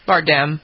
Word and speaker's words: Bardem: Bardem, yeah.